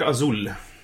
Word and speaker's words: Azul: Det heter Azul. Det betyder blå på spanska.